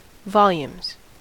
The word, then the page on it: volumes: American English